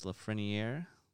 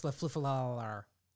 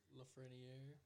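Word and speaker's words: Lafrenière: Alex Lafreniere. – Yeah, Alex Lafleniere. – Lafreniere.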